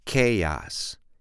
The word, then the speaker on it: chaos: Chaos. Chaos. Okay. Chaos has two syllables. Secondly, you need to understand exactly where the stress falls. Where is the emphasis? Chaos. I'm not saying chaos, I'm saying chaos.